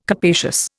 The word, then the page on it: capacious: Indian